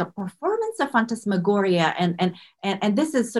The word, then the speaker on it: phantasmagoria: Absolutely, phantasmagoria.